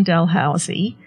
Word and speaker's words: Dalhousie: Her new book, The Place on Dalhousie, am I pronouncing that properly? – Yes.